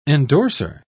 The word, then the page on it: endorsor: British English